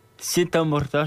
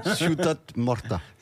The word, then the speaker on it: Ciutat Morta: Sieta Morta, je crois, si un truc comme ça. – La prononciation. – Ciutat Morta.